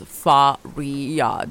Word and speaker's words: Faria: Then I started going by Faria because I thought, well, that's five letters. it's Faria. That shouldn't be something that gives you problems pronouncing it.